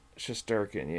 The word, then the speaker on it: Shesterkin: Shesterkin,